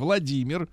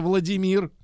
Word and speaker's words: Владимир: Почему американцы не могут говорить Владимир вместо Владимир, как они любят, О, и Владимир. Распутин вместо Распутин. вот как мы все время это слышим.